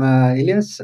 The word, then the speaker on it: Ilias: Yeah, I'm Ilias.